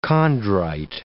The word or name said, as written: chondrite